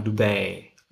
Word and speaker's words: Dubai: Nicht Dubai, sondern Dubai. Ich glaube, so wird das ausgesprochen, wenn ich mich.